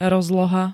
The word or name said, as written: rozloha